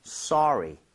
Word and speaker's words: sorry: 대신에 발음할 때 소리는 좀 심하죠. 소리는 아니니까 써에 가깝게 입모양 살짝만 벌려주고 발음했으면 좋겠어요. 그래서 yes. – 소리 이렇게 하지 마시고요. Sorry, sorry.